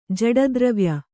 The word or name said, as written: ಜಡದ್ರವ್ಯ